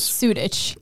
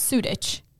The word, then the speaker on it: Sudich: People, everyone mispronounces it, but it's spelled how it sounds. Sudich. – That's CH at the end.